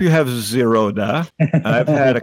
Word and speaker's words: Zerodha: It's it's Zerodha, you know.